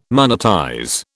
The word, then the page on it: monetize: British English